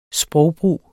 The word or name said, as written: sprogbrug